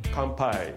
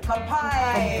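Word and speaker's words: Kampai (乾杯): How do you say it again? Kampai. – Kampai.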